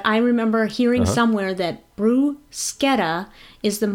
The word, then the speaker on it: bruschetta: It's not bruschetta. It's bruschetta.